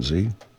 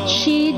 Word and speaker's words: Xi: President Z? How to pronounce, I gotta ask myself, how do you pronounce Z? – Xi Jinping. – Xi, President Xi Jinping.